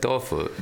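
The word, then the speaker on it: dofu (豆腐): Tofu, dofu, because... – Well, because that's why when I first started eating dofu was in China. – Tofu? – And they call it dofu, dofu.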